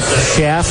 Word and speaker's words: Shafe: Let's see how Harold pronounces that. Chef. Just like chef with no T.